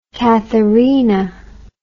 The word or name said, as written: katherina